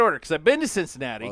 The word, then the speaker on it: Cincinnati: I want to drive from Cincinnati up to Cleveland. – Okay. – Did I say that right? – Yes, you're right.